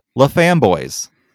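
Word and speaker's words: La Framboise: Or as the internet told me to say it, La Famboise. – I know you told me that before we started recording, but I just chuckle every time. I'm like, that's got to be a weird local pronunciation. – I cannot do it. So sorry, South Dakota, if that's really how you pronounce it.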